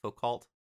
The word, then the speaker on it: Foucault: Foucault.